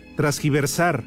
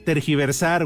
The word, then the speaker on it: tergiversar: Pues es que dice Felipe, dile al norteño que no se dice transgiversar. Es tergiversar, güey.